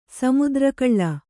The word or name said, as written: ಸಮುದ್ರಕಳ್ಳ